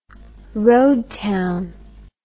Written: road town

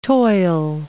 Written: toil

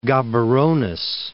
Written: Gaberones

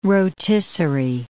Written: rotisserie

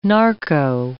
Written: narco

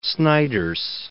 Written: Snyders

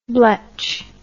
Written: bletch

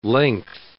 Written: length

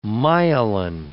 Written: myelin

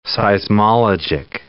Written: seismologic

